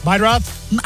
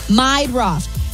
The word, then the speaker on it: Meidroth: Chase Murdoch? The White Sox guy? – Meidroth. – How do you say that? – Meidroth.